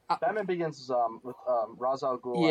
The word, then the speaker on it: Ra's al Ghul: Yeah, technically the correct pronunciation is Ra's al Ghul, even though it doesn't make any sense. – Ra's al Ghul. – They don't pronounce like that in the movie, but like it is technically pronounced.